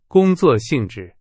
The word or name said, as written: 性质